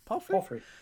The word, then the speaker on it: palfrey: Is that Palfrey? – Palfrey? – I'm not good at... Yeah. Palfrey? – Palfrey, sure.